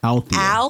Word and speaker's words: Althea: Althea. – So it's not. – No. – I try to throw it to her. – No, like, what we did there was pronounce the L that you missed.